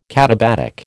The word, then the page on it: katabatic: American English